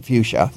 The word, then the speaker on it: fuchsia: The spelling of the name. F-U-C-H. Yeah, fuchsia. – Fuchsia. Hybrida. – Yes. But now, why isn't it? Okay, because I've heard it pronounced fuchsia. – Yeah. – It is. – I've heard it pronounced fuchsia. Shouldn't it be fuchsia or fuchsia? Because it's a CH.